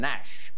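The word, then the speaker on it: gnash: A word meaning to grind or grate teeth together in rage. Vaughn? – What is G? – G in Nash.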